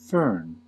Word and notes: fern: American English

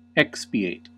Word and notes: expiate: American English

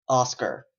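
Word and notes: oscar: Canadian English

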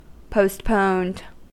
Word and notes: postponed: American English

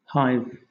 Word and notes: hive: British English

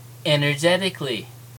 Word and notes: energetically: American English

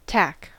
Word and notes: tack: American English

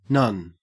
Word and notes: nun: Australian English

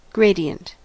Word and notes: gradient: American English

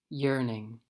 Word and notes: yearning: American English

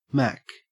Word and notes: mack: Australian English

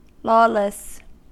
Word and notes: lawless: American English

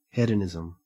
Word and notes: hedonism: Australian English

